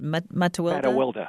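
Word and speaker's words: Mattiwilda: Matawilda, who became the opera star.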